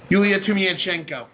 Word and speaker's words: Yulia Tymoshenko: There could be political instability if this woman, I wish I could pronounce her name, Yulia Tymoshenko,